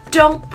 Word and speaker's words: don't: Don't push yourself. Don't push yourself. Don't push yourself. 这边会有一个略读的现象，don't 的 t 可以不发音。